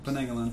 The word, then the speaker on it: penanggalan: Can you spell it one more time? – P-E-N-A-N-G-G-A-L-A-N. Penanagalan. Penangalan.